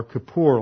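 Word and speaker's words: kippur (כיפור): It was used to translate this basic word in that we find in the Hebrew that's pronounced kafar or kippur, like Yom Kippur.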